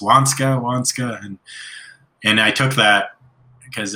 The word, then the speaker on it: Waniska: Wanishka? – So Wanska. Yeah. So Wanska, it's spelled W-A-N-S-K-A, but, or that's how it's pronounced.